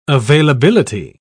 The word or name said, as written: availability